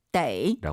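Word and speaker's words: 데이 (得): 동사나 형용사, 즉 수로 뒤에 있으면 떠라고 읽지만 수로 앞쪽에 있으면 데이. – 라고 읽습니다.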